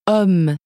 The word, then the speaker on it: homme: The same thing happens before many nouns that begin with H, such as homme. – Since H is silent in French.